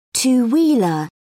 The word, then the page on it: two wheeler: British English